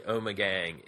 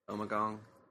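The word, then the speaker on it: Ommegang: Am I saying that right? Omegang? – Yeah, well, it could be gong, it could be gang. I go with gang.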